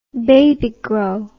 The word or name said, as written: Babygro